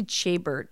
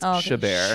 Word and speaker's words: Chabert: And I believe that is Lacey Chabert. – Yes, it is. I always said Lacey Chabert, but I'm sure that's wrong. – This is a Canadian film, so it's Chabert.